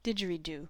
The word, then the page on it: didgeridoo: American English; /ˌdɪdʒ.ər.iˈduː/